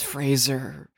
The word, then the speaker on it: Fraser: Same thing with Brendan Fraser. Everyone pronounced it like the Kelsey Grammer show from the 90s his entire life, and it took until the Whale campaign for him to be like, guys, it's Fraser.